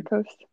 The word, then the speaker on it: varicose: She even has, oh, somebody said that she even has varicose veins on her legs. I think I said that wrong. – Varicose. – Varicose, there we go.